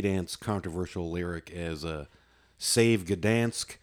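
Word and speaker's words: Gdańsk: So Gdansk is how you pronounce it, apparently.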